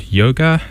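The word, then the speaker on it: Jóga: And the song is called Yoga, I believe it would be pronounced, J-O-G-A.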